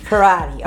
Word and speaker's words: Parati: So first, Chelsea, how do you say your last name? – Parati. – Parati. Okay. Yeah. So I was saying it wrong. – I always tell people it rhymes with karate. – Karate, Parati.